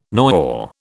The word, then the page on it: noyau: British English